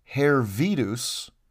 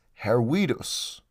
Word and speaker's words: Hervidus: Hervidus, as it's written in the charter, is spelled H-E-R-V, V as in Victor, by the way, I-D-U-S. Now, that is clearly the Latinized version of whatever the name was. But in Latin, the letter V isn't pronounced as V. It's pronounced as a W, like W. Therefore, you wouldn't read it as Hervidus, you would pronounce it as Hervidus.